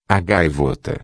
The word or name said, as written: gaivota